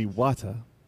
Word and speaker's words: Wata: You said it wrong, man. – Wata Guan. Book. – It's Wata.